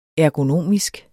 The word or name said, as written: ergonomisk